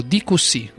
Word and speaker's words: DQC: E andiamo a sentirci un'altra canzone, la canzone che apre questo disco, DQC. Lo pronuncio bene? – Sì, lo pronunci benissimo.